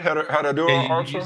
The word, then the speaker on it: Herradura Ultra: Did I say that right? Herradura Ultra? – You said it right.